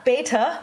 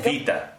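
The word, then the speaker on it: vita (βήτα): The second letter of the Greek alphabet is not beta, which we all think. – Vita. – It's vita. – It's vita. – And it's pronounced like a V.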